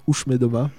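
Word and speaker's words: Už jsme doma: Alors, le groupe, ça se prononce Ushmedoma.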